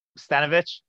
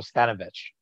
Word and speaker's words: Stanovich: But I've mispronounced his name probably all season. So Stanovich, thank you. So like Stennies, but Stanovich. Okay, I got it.